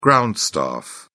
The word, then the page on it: ground staff: British English